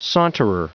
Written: saunterer

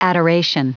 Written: adoration

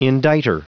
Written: indictor

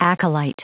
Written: acolyte

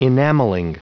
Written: enamelling

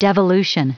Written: devolution

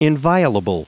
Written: inviolable

